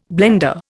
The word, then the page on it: blender: Indian